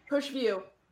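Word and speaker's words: Pusch View: P-U-S-C-H. – V-I-E-W. – Pushview.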